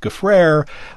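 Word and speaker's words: Gfrörer: It is spelled G-F-O with an umlaut, R-E-R. That's G-F-O with an umlaut, R-E-R, but it's pronounced Gaffrere like despair.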